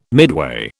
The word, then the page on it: midway: British English